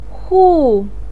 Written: hu4

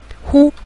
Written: hu5

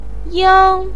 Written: yang1

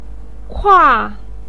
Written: kua4

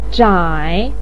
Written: zhai3

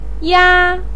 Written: ya1

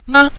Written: ma5

